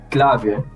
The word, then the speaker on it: Clave: Sergio said on last time on here, he actually said my name, Clave, which is actually how you pronounce it in like here at Aruba, but that's how you actually pronounce it. But more simple for everyone, just say it Clave.